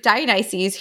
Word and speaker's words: Dionysus: So we meet Dionysus, who's already three sheets to the wind.